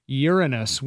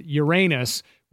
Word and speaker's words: Uranus: Oh, well, see, according to Wikipedia, the pronunciation of the name Uranus preferred among astronomers is Uranus, with stress on the first syllable, in Latin Uranus, to contrast Uranus, with stress on the second syllable.